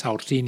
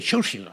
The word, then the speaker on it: Saoirse: que no se dice así para nada, no es Saoirse ni Saoirse, se dice Churchill o algo por el estilo.